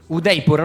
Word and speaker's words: Udaipur: Udaipur.